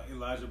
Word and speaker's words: Elijah: Elijah Bawley Jr. First name is E-L-I-J-A-H. Last name is G-B-O-L-L-I-E and Jr. Okay.